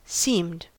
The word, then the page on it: seemed: American English